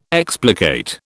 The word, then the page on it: explicate: British English